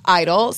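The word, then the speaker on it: (G)I-DLE: As we learned last week, they're actually pronounced Idol, spelled G-Idol.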